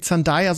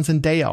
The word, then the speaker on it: Zendaya: Und übrigens, ich habe gelernt, sie wird nicht Zendaya, sondern Zendaya ausgesprochen.